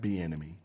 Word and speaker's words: Bieniemy: The news of the leaving of Eric Bieniemy. Did I say that right? Be enemy.